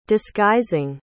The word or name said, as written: disguising